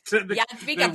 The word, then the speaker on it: Jadwiga: And that it's, I mean, the Hedwig, Jadwiga, is that how you say it? – Jadwiga, very good.